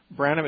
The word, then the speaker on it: Branham: How do you say his last name? – Branham, it's Branham.